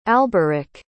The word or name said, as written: alberich